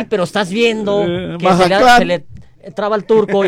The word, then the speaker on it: Mazatlán: Pero no es Mazatlán, estás equivocado, se pronuncia Mahatlán.